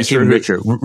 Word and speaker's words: Stephen Richer: Stephen Richer, is that how you say his name? – Yeah, Stephen Richer.